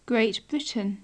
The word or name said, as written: great britain